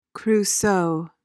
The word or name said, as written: Crusoe